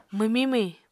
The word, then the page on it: mwmiñ-mwhiñ: Apatani